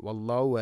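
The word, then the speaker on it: വല്ലാഹു (وَاللَّهُ): വല്ലാഹു, ഇവിടെ ഈ വല്ലാഹു എന്നുള്ള പ്രയോഗം പലപ്പോഴും നമുക്ക് കാഴ്ചയിൽ വളരെ എളുപ്പമായിട്ട് തോന്നും, പക്ഷെ പലപ്പോഴും ഉച്ചാരണത്തിൽ ഈ ഹ ഒന്നും വരാറില്ല. വല്ലാഹു അല എന്ന് പറയും. വല്ലാഹു അലാ കുല്ലി ഷൈഇൻ ഖദീർ, വല്ലാഹു അലാ കുല്ലി ഷൈഇൻ ഷഹീദ്, ഇങ്ങനെ കേൾക്കാം നമുക്ക്. അഥവാ ഹ ഉച്ചാരണത്തിൽ തന്നെ ഉണ്ടാവില്ല, വല്ലാഹു അല എന്ന് പറയും. അതുകൊണ്ട് കൃത്യമായിട്ട് വിശുദ്ധ ഖുർആൻ പാരായണം ചെയ്യണം.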